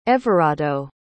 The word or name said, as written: evarado